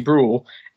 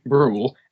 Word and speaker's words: Brawl: Brawl, and apparently I pronounce my my name, Brawl, as a B-R-O-O-L, which is not obviously as it's spelled.